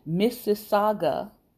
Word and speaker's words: Mississauga: Edmonia Lewis comes out of the, let me make sure I say this right, Mississauga Anishinaabe people. Think about Mississippi, but Mississauga Anishinaabe people.